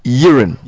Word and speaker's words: Jeroen: It's spelled J-E-R-O-E-N. Right? But it's pronounced Yeren. So V-E-R-H-E-U-L pronounced Yeren. And then the surname V-E-R-H-E-U-L is pronounced Verheul. Verheul. Verheul. So the V is F and the J is Y.